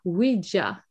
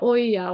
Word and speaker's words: Ouija: Eh, och det är därför jag heter Ouija, alltså oui på franska och så ja på tyska, Ouija. Mm. – Så att en del uttalar oja och sånt där, för man vet inte hur det ska uttalas, men Ouija.